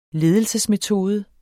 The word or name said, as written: ledelsesmetode